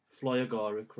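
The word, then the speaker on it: fly agaric: The Flyalargic, I might be saying that wrong. Flyagaric, rather, the Amanita muscaria.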